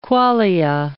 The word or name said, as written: qualia